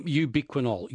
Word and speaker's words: ubiquinol: Yep, ubiquinol. B for Betty, U-B-I-Q-U-I-N-O-L.